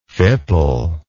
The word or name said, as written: fipple